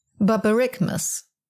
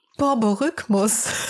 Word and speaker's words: Borborygmus: Englisch? Moment. Borborygmus. Wird es auf Englisch ausgesprochen. – Hervorragend. – Ich habe es mir extra von dieser Ausspracheseite jetzt mal vorlesen lassen. Und im Deutschen, Moment. Borborygmus. Dann war meine Aussprache ja gar nicht mal so bescheiden.